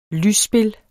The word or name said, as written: lysspil